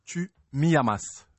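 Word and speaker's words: Tú me amas: La prononciation, c'est Tu me amas.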